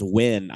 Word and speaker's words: Nguyen: His friend Matt Nguyen, I think is how you say it. – I think it's pronounced Wynn.